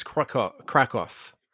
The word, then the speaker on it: Kraków: Now, where is Kraków? I'm sorry if I said that wrong. – No, it's okay. I think that's actually like the common English pronunciation, so that's good.